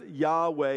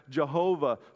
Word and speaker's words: Yahweh: The name often pronounced Yahweh is used over 6,000 times in the Old Testament. Sometimes it's pronounced Jehovah. Sometimes it's just Yah for short.